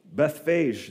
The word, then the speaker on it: Bethphage: Anybody know? Bethphage? Bethphage, there we go.